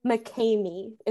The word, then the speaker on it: McKamey: McKamey. It's weird. McKamey. It's the guy's last name.